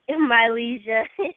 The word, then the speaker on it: Mylezia: Mylezia.